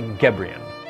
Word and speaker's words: Gebrian: It's Molly Gebrian.